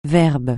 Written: verbe

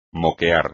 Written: moquear

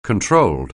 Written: controlled

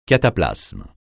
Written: cataplasme

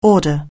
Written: order

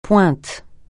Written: pointe

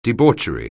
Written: debauchery

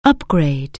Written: upgrade

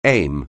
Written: aim

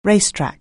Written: racetrack

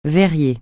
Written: verrier

